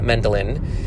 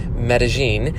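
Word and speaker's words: Medellín: It's definitely not Mendelin, Medellin.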